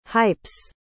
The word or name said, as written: hype's